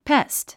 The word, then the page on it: pest: pést; ペスト